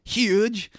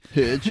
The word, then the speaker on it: Huge: Tom Huge. – Hidge. – Hidge. It's probably like Hugh or something like that, but it's spelled like Huge.